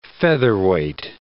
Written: featherweight

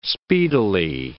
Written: speedily

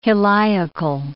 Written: heliacal

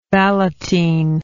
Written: ballottine

